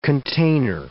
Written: container

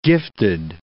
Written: gifted

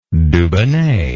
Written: dubonnet

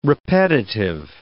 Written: repetitive

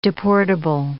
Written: deportable

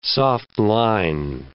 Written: soft-line

